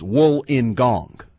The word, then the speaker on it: Wollongong: Ike, our boy in Australia, checks back in, wants to correct me on the pronunciation as well. It's Wollongong. Even though it's spelled Wollongong, it's Wollongong.